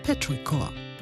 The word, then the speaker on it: Petrichor: Zusammen, englisch ausgesprochen, Petrichor.